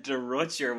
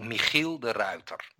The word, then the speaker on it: Michiel de Ruyter: Well, if you'd asked me about 6 months ago, I would have said De Rutger, which is obviously wrong. And even the later version of how I think, because I realized I was spelling it wrong all this time, not even just pronouncing it wrong. Now I think it's De Ruyter, but I'm probably wrong with that as well. – Okay, so in Dutch we say Michiel de Ruyter, and nobody will.